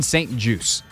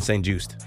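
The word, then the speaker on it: St-Juste: And that is that his name is said Benjamin St. Juice, as in a juice box, as in apple juice, orange juice. It is French-Canadian, or Canadian-French, more specifically. There's no T pronounced.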